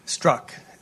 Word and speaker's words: Strzok: Strzok.